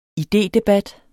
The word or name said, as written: IDEDEBAT